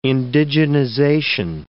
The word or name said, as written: indigenization